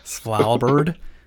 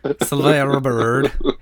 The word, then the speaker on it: Svalbard: Svalbard? Svalbard. – It's like right in the back of your throat.